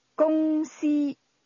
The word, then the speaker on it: 公司: That's pronounced with two high-level tones. – 再讲一次。 – Stores. – 公司。